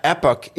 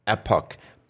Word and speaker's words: Epoch: But Epoch is the standard, I believe, preferred pronunciation. Epoch, puck as in a hockey puck.